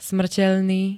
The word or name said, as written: smrteľný